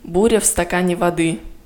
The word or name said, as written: Буря в стакане